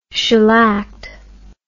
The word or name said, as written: shellacked